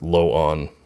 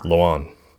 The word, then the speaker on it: Loan: Loan is pronounced Lo-on, but said really fast with emphasis on the on part. Loan. Oh, so even I mispronounced it again. – Loan. – Loan. Just a friendly FYI, since I know you're all out there saying loan like car loan.